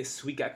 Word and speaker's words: Suica: And you want to get a Suica card. It's like a little penguin on the card, and it's spelled S-U-I-C-A, Suica.